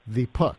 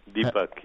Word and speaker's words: Deepak: you say his name, Deepak. – Deepak, yes. – Say it right. – Yeah, Deepak.